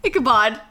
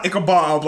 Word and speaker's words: Ichabod: Ichabod. – So it's not Ichabob, like a no, bob wig.